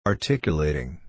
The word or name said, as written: articulating